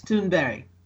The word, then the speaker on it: Thunberg: But in Swedish, it's Thunberg. That's how it's pronounced.